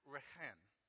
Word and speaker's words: rechem: The word for womb is pronounced rechem.